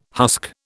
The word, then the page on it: husk: British English